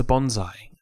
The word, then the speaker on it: bonsai: No, it's a bonsai. Close. You nearly got the pronunciation.